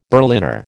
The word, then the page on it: Berliner: American English